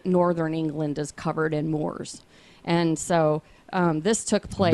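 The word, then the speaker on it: Moors: The Moore's, like the English Moore's. – M-O-O-R? – Yes. – Okay, are you sure that's how you say it? – That's how you say it.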